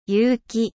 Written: 有機

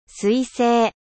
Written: comete

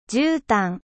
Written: 絨毯

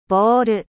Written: ballon

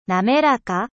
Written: lisse

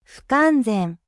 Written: incomplet

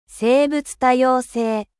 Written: 生物多様性

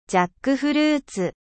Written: jacquier

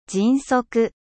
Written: 迅速

